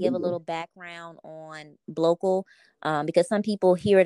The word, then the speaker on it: Blocal: So Blocal is spelled B as in black, L O C as in cash, A L, and then it's the word search. And Blocal is a mashup of black and local.